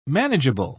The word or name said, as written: manageable